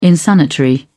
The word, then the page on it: insanitary: British English